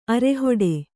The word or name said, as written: ಅರೆಹೊಡೆ